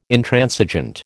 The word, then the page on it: Intransigent: American English